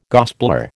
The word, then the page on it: gospeller: American English